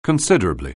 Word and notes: considerably: anglais

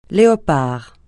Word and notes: léopard: francais

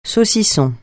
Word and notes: saucisson: francais

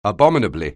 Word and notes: abominably: anglais

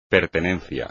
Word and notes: pertenencia: espagnol

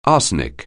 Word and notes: arsenic: anglais

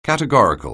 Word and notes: categorical: anglais

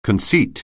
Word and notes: conceit: anglais

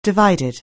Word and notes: divided: anglais

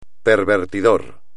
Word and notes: pervertidor: Spanish

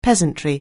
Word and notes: peasantry: anglais

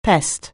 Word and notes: pest: anglais

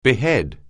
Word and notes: behead: anglais

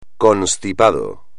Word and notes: constipado: espagnol

